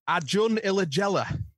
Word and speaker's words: Acun Ilıcalı: Your owner, Akun Ilıcalı, is it, or Ilıcalı? – Acun Ilıcalı.